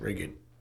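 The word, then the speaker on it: Ridgid: It's pronounced rigid. – Rigid? – Yeah. – It's rigid.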